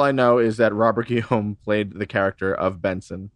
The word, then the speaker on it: Guillaume: It's Guillaume.